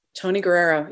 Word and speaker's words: Guerrero: Am I saying his name right? – Tony Guerrero. Yes, he's amazing.